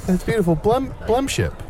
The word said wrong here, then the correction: blendship: Am I saying it right? Blemship. – Blendship. Blendship. It's the perfect blendship.